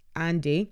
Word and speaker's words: Ahndy: So yeah, again, Elizabeth Marsden go, yes, I'm going to shamelessly promote our benchmark report that Wizard pronounced Andy, that's the handle, pronounced Andy, so A-H-N-D-Y, authors every quarter.